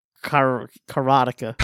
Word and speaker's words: Karateka: So Karateka means a practitioner of karate. Karate. – That makes sense. Which is why you would pronounce it Karateka.